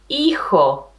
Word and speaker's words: hijo: We are going to say, remember, we said the H is silent in Spanish. So we're going to say hijo.